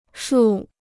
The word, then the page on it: 树: shù